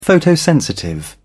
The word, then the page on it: photosensitive: British English